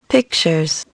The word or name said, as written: pictures